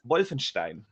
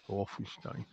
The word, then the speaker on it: Wolfenstein: It's Wolfenstein. Yeah. – Wolfenstein.